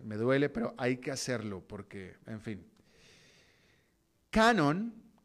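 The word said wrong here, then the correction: QAnon: que se escribe con Q, quizá tal vez fuera Quanon, más, más correctamente, Quanon.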